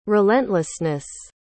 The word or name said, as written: relentlessness